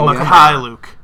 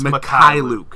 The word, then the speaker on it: Mykhailiuk: Oh, Mykhailiuk. It's Mykhailiuk. – Come on, Brennan. Mykhailiuk. That's not even that hard.